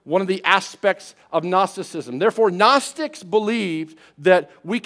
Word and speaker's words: Gnosticism: It was called Gnosticism. Gnosticism, spelled with a G.